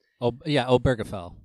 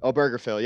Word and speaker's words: Obergefell: A lot of people are scared about this, though, and that she would try and overturn the Obergefell decision. I'm not sure if I'm saying that right. – Obergefell. – Obergefell, yeah.